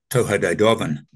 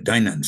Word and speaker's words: Tuatha De Danann: It's spelt Tuatha De Danann, but it's pronounced, I think, Toha De Dovan. But I pronounce it Danann since it's set back in a mythical time of about the Bronze Age, about 1700 BC, is how I'm imagining it.